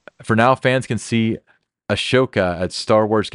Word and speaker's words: Ahsoka: Okay, Ahsoka.